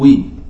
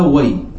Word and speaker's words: وي: يكسرون الواو، يكسرون الواو، يقولون وي وي بكسر الواو. طيب هل توجد لغة هكذا؟ لا أدري، نحتاج أن نرجع للمعاجم والقواميس، لكن القرآن نطق بها وي، وهي هكذا ذكرها المصنف، وي بفتح الواو. عندكم تنطقونها بالواو مفتوحة. جميل.